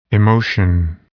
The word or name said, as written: emotion